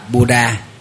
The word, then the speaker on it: Buddha: Nguyên văn cái chữ đó đọc là Buddha, chúng ta phiên âm là Phật Đà, người Trung Hoa phiên âm là Phật Đà.